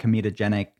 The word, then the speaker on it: comedogenic: Did I say that right? Comedogenic? – Yeah. – It sounds in my mind, every time I'm saying that, I'm thinking of comedic. But it's not funny. It's not funny at all.